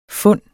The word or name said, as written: fund